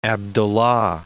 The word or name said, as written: Abdullah I